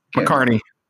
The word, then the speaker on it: McCartney: is it McCarty? McCartney? – McCartney. Dan McCartney.